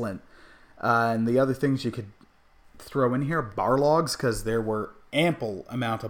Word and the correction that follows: Balrog: Or I'm not saying it right, Balrog.